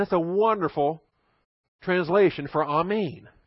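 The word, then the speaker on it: amen (אָמֵן): The Hebrew word is also amen. It's pronounced just like the Greek, amen.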